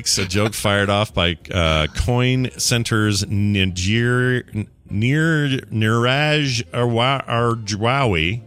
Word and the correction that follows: Agrawal: Yeah, I think it's Agrawal. – I thought that L was an I.